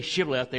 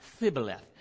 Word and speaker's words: Shibboleth: And then he would say, you know, and I guess the Ephraimites all had a lisp or something like this because they couldn't say Shibboleth. It always come out Thibboleth. And so it says they couldn't pronounce it right.